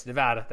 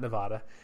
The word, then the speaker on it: Nevada: Yes, Nevada. That's the correct way to say it, not Nevada.